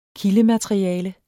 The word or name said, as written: kildemateriale